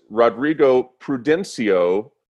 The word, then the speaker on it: Rodrigo Prudencio: We also have joining us Rodrigo Prudencio from the Alexa Fund.